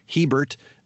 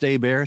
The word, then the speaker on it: Hebert: That's spelled like Hebert, but it's pronounced Hebert. It's a Louisiana French thing.